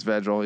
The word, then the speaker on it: Vedral: Well, and your quarterback, Noah Verdell or Vedral. How do you pronounce that? – I think it's Vedral. Yeah.